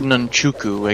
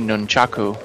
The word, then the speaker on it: nunchaku: And for Casey, the ancient, and if I may say, highly dangerous, Nantruku. – Did he pronounce that right? – No problem. – No. He said nunchuku. I guess it would be nunchaku.